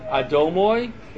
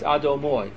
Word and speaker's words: Adonai: The accent's on the last syllable. So it's not Adoshem, it's Adoshem. I'll say it with a mem, just to make sense. It's not Adomoy, it's Adomoy.